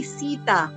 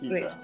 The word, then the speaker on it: Sita: Yeah, because the T is a little bit silent, I guess, or it sounds like a D. So it's like Sita, but I do mean to say Sita.